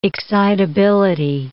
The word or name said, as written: excitability